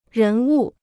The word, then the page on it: 人物: rén wù